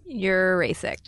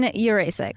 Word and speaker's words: Urasek: Urasek. – Urasek. – Lauren Urasek.